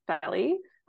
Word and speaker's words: Felly: um, but yeah, so my name's Felly. Um, it's pronounced like Kelly with an F.